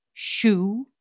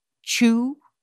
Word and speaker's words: Zhu: So, for example, you might have the name Zhu, Z-H-U. Now, depending on where the student is from, Zhu can be pronounced Zhu, Shu, Zhu, Chu, and those are just a few examples.